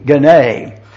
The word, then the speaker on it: gune: G-U-N-E, gune. Some have pronounced that goon, and I wouldn't do that for the world. But anyway, it is the word gune, G-U-N-E.